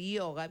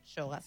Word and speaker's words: yoga: Y si bien estas cosas y estas respiraciones vienen de raíces que están en el yoga, viste que yo ahora digo yoga porque Luis Majul me enseñó que no se dice yoga, sino se dice yoga. – Claro, porque viene del sánscrito, no es la yoga, es yoga.